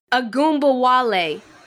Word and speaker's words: Ogunbowale: Ogunbowale. – Look, she ching. – A Goomba Wale.